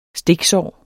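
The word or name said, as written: STIKSÅR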